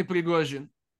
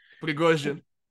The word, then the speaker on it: Prigozhin: Yevgeny Prigozhin.